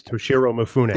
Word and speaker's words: Toshiro Mifune: It was the guy from the Godzilla movies, Toshiro Mifune.